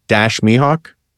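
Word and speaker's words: Dash Mihok: You have a great ensemble cast, and I think I'm pronouncing it right. Dash Mihawk. – Yeah.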